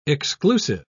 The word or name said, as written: exclusive